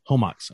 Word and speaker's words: Comox: Is that, I pronounced that correctly? – Comox.